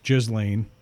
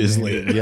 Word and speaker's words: Ghislaine: And if everything comes out in the wash, and that's what I think this Ghislaine, and that's how I pronounce it. – Ghislaine. Yep.